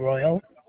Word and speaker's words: Royal: Charlene Royal.